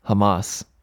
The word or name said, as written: Hamas